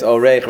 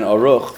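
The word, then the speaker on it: Orech: Sholchan Oreich, which is, by the way, it's Oreich rather than Oruch, for those who have been losing sleep over that, because it has to rhyme.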